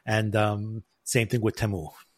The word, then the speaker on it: Temu: Yeah, the song is like, I'm not going to sing it, but they basically say that it's Temu in the commercial.